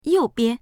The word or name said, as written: you4bian